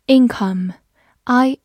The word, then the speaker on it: income: income，i n c o m e，income，i n 发音，c o m e come，income，income，或者字母 o 也可以发弱读的 a，income 也是可以的。